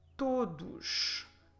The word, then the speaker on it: todos: The word for everyone is the word todos. Todos. Como se escreve todos? T-O-D-O-S.